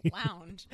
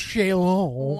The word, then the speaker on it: chaise longue: You can say lounge. – It's pronounced chaise longue.